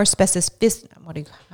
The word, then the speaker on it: specificity: That gives us a little more specificity. How do you say that word? Specificity? – There you go.